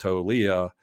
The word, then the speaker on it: Toglia: his last name is spelled T-O-G-L-I-A, but it's pronounced Tolia.